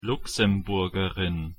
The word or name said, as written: luxemburgerin